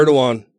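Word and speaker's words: Erdoğan: Erdogan. – What? – That's how it's pronounced.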